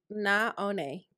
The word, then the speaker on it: Naone: Naone Carter.